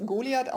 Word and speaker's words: Goliath: Richtig, und ich glaube, man darf es Goliath aussprechen. Ähm, ich habe irgendwo einen Werbespot gehört und da haben sie es äh, so ausgesprochen. Also wir blamieren uns nicht, wenn wir das TH umgehen heute mal.